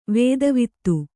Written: ವೇದವೇತ್ತ

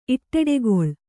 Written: ಇಟ್ಟೆಡೆಗೊಳ್

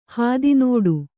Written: ಹಾದಿನಡೆ

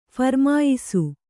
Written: ಫರಮಾಯಿಸು

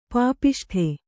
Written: ಪಾಪಿಷ್ಠ

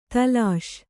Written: ದೋಲಿಸು

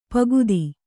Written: ಪೋಕ್ತ

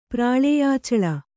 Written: ಪ್ರಾಳೇಯಾಚಳ

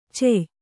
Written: ಛಾಯ

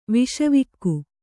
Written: ವಿಷವಾಕ್ಕು

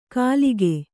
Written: ಕಲಿಕೆ

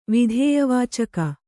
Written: ವಿಧೇಯವಾಚಕ

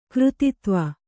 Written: ಕೃತಿತ್ವ

